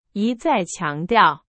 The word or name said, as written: 一再